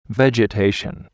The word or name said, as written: vegetation